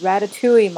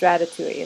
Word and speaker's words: ratatouille: I always want to say, I put too many syllables. Will's like, it's ratatouille, Mom. It's ratatouille.